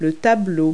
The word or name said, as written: painting